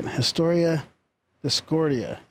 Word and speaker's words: Historia Discordia: Historia Discordia. I don't think I said that right. Historia Discordia. – Yeah, maybe. That sounds right.